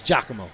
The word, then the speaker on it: Giacomo: How do you say the name? Giacomo? – It's Giacomo, yeah.